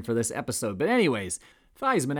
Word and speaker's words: Theismann: Theismann, which is actually in truth pronounced Theismann, but the All-American made a run at the 1970 Heisman Trophy, which is why the pronunciation of his last name was changed, kind of a marketing move to rhyme with Heisman.